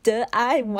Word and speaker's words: De An: I saw Tian, and I'm just like, that's not my name. My name is De An. Why do they translate to Tian? Okay. And I didn't even know how to pronounce my own name.